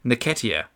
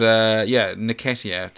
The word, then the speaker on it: Nketiah: I think it's like Nketiah. No, you even had it a while ago. It's like Nketiah.